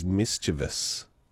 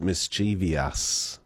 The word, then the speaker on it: mischievous: Yes, that one is, I believe the proper way to say that is mischievous. Is it? Mischievous. Often pronounced mischievous.